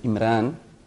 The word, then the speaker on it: Imran: Imran, or they might use it in a sense like Ran.